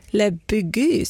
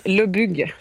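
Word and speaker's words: Le Bugue: Och jag tror att du får hjälpa mig nästan med hur man uttalar Le Bugy, säger man så? – Inte riktigt. Jag kan inte heller prata, eller uttala det när jag flyttar. Le Bugy, säger man.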